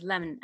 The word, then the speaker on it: Lemn: So my second book is Lemon, as he pronounces it, Lemon Cisse.